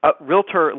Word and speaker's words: Realtor: Realtor listing, I have agents telling me to pronounce it right. So listeners, I'm going to say it right. Realtor listings, resale listings primarily, obviously new construction as well.